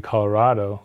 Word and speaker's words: Colorado: Colorado, you don't say avocado, right? So it should be Colorado, like avocado, like tostado, right?